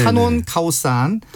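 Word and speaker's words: 타논 카오산 (ถนนข้าวสาร): 그 앞에 타논이라는 게 길이라는 뜻인데 네네네. 타논 카오산. – 타논 카오산. – 이렇게 발음을 해야 간다라는 것이고 예.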